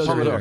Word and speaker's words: Pomodoro: Yeah, Pomodoro.